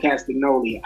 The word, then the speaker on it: Castagnoli: like we had Dustin Rhodes versus Claudio Castagnoli. Did I get his name right? Did I say his name right? I said it right.